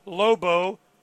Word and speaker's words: Lobo: Maria, M-A-R-I-A, Lobo, well, that's how I say it. – L-O-B-O, Murga, M-U-R-G-A, Maria Lobo Murga, and from Argentina.